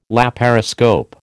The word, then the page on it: laparoscope: American English